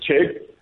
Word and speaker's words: Chegg: So I love your pronunciation, Simon. That is a South Africanism if there ever was one. we do pronounce the J as a K, but it's Chegg.